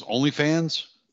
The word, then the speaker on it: OnlyFans: She has one of those OnlyFans. Did I say it right? – OnlyFans. – Yes, you did.